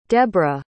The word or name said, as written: debora